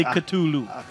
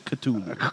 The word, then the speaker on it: Cthulhu: Non, c'est Cthulhu. C'est comme ça qu'on le prononce. – Cthulhu. – C'est que si vous avez appris quelque chose au moins ce soir, c'est qu'on dit Cthulhu.